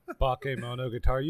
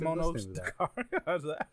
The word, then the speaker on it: Bakemonogatari: You better say that name with some respect. – Sorry. – How dare you? – As I was saying, I was like, I'm butchering it. – Yeah, that hurt me. I felt insulted. Bakemonogatari.